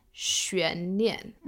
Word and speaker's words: 悬念: 悬念. Okay. Second tone, fourth tone, 悬念.